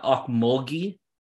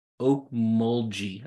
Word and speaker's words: Okmulgee: So Impact Rehab Wellness, and it is not, hold on, it's not Okmulgee, it's Okmulgee, I think is correct, is how you say the town.